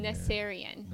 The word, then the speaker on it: Naserian: I don't want to butcher it on Naserian.